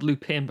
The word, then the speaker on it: Lupin: Spelled Lupin, but Lupin's the technical correct pronunciation. – Because French.